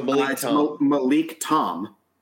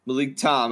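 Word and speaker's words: Malik Thom: THOM. How do you say that? – Oh, Malik Tom. – Malik Tom. Yeah, I thought the H is silent, apparently.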